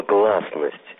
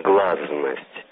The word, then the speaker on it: гласность: Помню, как в первую поездку в Италию, еще при Горбачеве, был поражен местной модой на слово «гласность», произносимой здесь, согласно фонетическим традициям, как «глазность». «С» перед другой звонкой согласной всегда звучит как «з».